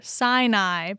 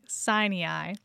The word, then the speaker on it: Sinai: The S stands for Sinai, but you were saying Sinai. And we uncovered the reason for that. It's not because that's the Hebrew way to pronounce it. – That's right. That is the redneck way, baby.